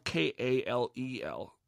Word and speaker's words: Kal-El: It's spelled K-A-L-E-L, no space. So it's Kal-El, but with no space between the Kal and the L.